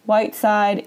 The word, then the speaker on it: Whiteside: And my name is spelled T-A-B-A-T-H-A. Whiteside is the color white. S-I-D-E.